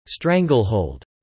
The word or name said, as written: stranglehold